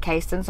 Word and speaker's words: Caston: And it's the only Caston I've ever other uh, met but I've recently learned that she pronounces it Kasten, so I'm not angry anymore. I was angry for a minute, but now I'm not. You won whatever competition that was. – Kasten. Kasten, and it's spelled exactly the same.